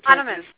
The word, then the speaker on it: Hahnemann: Because I have a Hahnemann.